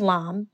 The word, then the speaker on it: Lam: The way in which I introduce myself is I say, hi, my name is Lam. It rhymes with mom, and you will find out why.